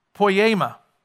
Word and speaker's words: poiema: So that original word that's translated masterpiece in English is a word that's pronounced poiema.